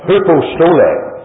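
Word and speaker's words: hupostole (ὑποστολή): It was an English pronunciation. That's hupostole.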